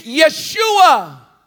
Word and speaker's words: Yeshua: Jesus, that's the Greek. That's our English Greek pronunciation. At that, there's power in the name of Jesus. But in Hebrew, it's Yeshua. When you look it up, it's Joshua.